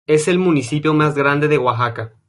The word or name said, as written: grande